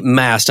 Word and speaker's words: Mast: Well, tell us about the company, Mast. I assume I'm pronouncing that correctly. – You got it right.